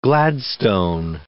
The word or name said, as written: Gladstone